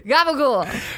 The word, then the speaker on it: gabagool: It's gobagoo. – No. – Gava gava. What the fuck is gava gava? – How do you say it? – Not a turkey. It's gabagool.